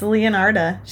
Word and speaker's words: Leonarda: But so Leonardo uh Cianciulli, I'm not gonna say her name any early. I said Leonardo. It's Leonarda. She's a lady.